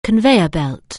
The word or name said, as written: conveyor belt